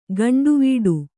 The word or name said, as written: ಕೊಂಡವಾಡೆ